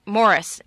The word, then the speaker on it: Maurice: A parent requested the removal of Maurice, the movie that it was adapted from E.M. Forrester's book. Morris is actually how it's pronounced, which is a book about teenagers in high school in England.